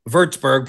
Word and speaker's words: Würzburg: And for people who don't know, Würzburg, pronounced properly, the home of Dirk Nowitzki, the home of Maxi Kleber.